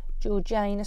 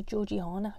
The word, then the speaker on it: Georgiana: I watched The Duchess for the second time with Keira Knightley, and she plays Georgiana Spencer, although I always want to call her Georgiana because that's how it's spelt, who married the 5th Duke of Devonshire.